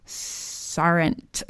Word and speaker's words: sergeant: So the word is sergeant, but it's really weird because they pronounce it, yes, sergeant, like, or here first are, like, it's almost like you lose all those middle vowels or consonants in the center there, and it's sar.